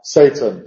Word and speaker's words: Seyton: En el caso de Macbeth, el portero se llama Satan, que es como nosotros pronunciamos Satán, Satanás, etc. Se escribe S-E-Y-T-O-N, pero se pronuncia lo mismo, Satan.